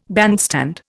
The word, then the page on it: bandstand: Indian